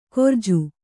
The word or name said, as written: ಕೊರ್ಜು